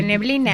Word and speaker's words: neblina: De neblina. – De neblina, sí. – De nebul. – Neblina, se dice.